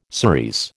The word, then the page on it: cerise: American English